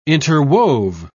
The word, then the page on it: interwove: British English